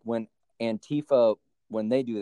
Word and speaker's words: Antifa: Yeah, it's Antifa.